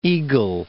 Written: Eagle Lake

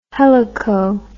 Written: helico

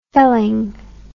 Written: felling

